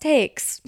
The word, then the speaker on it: Taix: And it's a French restaurant, but it's pronounced Takes. T-A-I-X. But you pronounce the X.